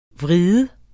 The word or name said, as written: vred